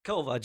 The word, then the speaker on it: Kowa: Kowa, just Kowa, Flo Kowa.